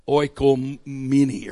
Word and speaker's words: oikoumene: Three primary Greek words translated by the English word W-O-R-L-D. One is cosmos, one is eon, and then there's this one that's a little harder to pronounce. It is O-I-K-O-U-M-E-N-E. Oikomene or whatever.